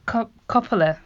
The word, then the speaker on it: Coppola: Yeah, well, I know that Coppola, am I saying that right? – You are.